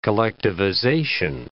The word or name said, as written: collectivization